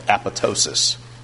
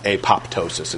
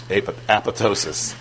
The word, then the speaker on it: apoptosis: And this brings about programmed cell death, which is called apoptosis. That's how this word is pronounced. Not apoptosis, it's apoptosis.